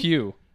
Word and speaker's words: Pugh: I don't know how to pronounce her last name. – It's Pugh. – Oh, yeah. Oh, because I'm British.